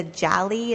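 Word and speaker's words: Jalie: Is it the Jali? Is that how you pronounce it? 2805? – Oh, goodness. Yes.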